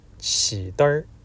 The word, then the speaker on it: 曲灯: 此后常年在鬼市摆摊做买卖，他那买卖做得和别人不同，地上摆几包曲灯，曲灯就是火柴，老言古语叫曲灯，念出来要念成起灯，在鬼市上换曲灯叫换软骨，曲灯有名的意思，名字同敏，是告诉别人专收老坟里掏出来的东西。